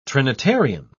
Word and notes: trinitarian: British English

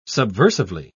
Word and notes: subversively: British English

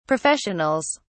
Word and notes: professionals: British English

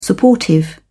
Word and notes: supportive: British English

